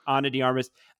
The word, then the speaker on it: Ana de Armas: I think it's Ana de Armas. Yeah. – Okay. Ana de Armas.